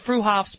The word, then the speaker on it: Freehoff's: Spelled Freehoff's, pronounced Freehoff's at 33rd and Arapaho in Boulder.